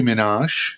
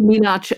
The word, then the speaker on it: Minaj: A americká reperka z Queensu, Nicki Minaj, jestli to vyslovuju dobře. – Já myslím, že jo, slyšela jsem to vyslovat Minaj, ale whatever, to je prostě, prostě Nicki.